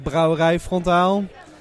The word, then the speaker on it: Brouwerij Frontaal: Ladies and gentlemen, I'm standing here at F- uh, Brewery Frontal at All In Brewfest. Hi, man. Nice to be here. Was that pronunciation okay? – Uh, somewhat. It's like Brouwerij Frontaal. Uh, so it's close, but yeah.